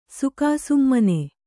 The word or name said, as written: ಸುಕಾಸುಮ್ಮನೆ